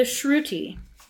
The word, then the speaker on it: Shruti: The Shruti.